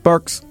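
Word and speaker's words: Barq's: I guess Barks. Is that how it's pronounced? – B-A-R-Q? Yep. Q, yeah. Barks.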